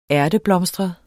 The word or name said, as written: ærteblomstret